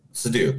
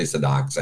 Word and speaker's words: Seydoux: Seydoux, thank you. I thought I was going to say Sadox.